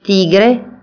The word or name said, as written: व्याघ्रः